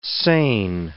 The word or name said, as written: sane